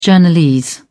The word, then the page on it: journalese: British English